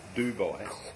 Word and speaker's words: Dubai: despite the marble and the free-flowing Moet in the airport at Dabai. – Dabai? Is that the new pronunciation, is it? – I didn't know there was any other. I'd like to dab my eye in the... – Dubai.